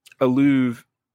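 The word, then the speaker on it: Illouz: You mentioned a book, The End of Love, by sociologist Eva Alouz. – Yeah. Oh, yeah. Thank you. Alouz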